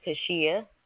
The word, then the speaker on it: Kashea: My name is Kashia. – Can you spell that, please? – K-A-S-H-E-A.